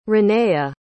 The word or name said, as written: renea